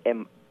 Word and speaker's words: Emetrol: Mua ngoài đó chị chỉ mua cái thuốc tên là Emetro, chứ Emetrol đọc là Emetro.